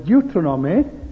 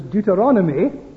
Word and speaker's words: Deuteronomy: Now, it doesn't matter how you pronounce it. I call it Deuteronomy. The old way, I think, was Deuteronomy. But both are acceptable. Both are correct.